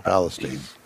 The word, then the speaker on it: Palestine: No, we ran at, I don't want to mess up the pronunciation here. Is it Palestine? – Palestine.